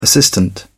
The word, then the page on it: assistant: British English